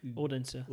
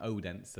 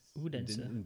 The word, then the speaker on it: Odense: Odense. Yeah. – I can't pronounce it. I got pulled up on that by calling it Odense, and it's not Odense, it's Odense.